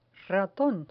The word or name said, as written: raton